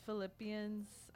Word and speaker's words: Philippians: In verse Philippians, I said that right, yep.